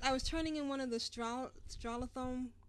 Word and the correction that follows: Stratholme: I can never say that instance, right? – Stratholme. – I always want to put an extra syllable in there.